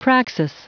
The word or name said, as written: praxis